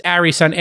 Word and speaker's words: Arison: It's Arison, A-R-I-S-O-N, correct? – That's correct.